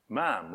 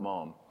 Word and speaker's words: ma'am: Ma'am rhymes with spam. That's what they told me to say. You always say ma'am, not mom.